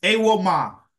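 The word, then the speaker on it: Evbuomwan: Trey Jameson and Tyshawn Iwuamu. – Yeah, that was close. That was pretty close. Yeah, it's Awoma. Mm-hmm.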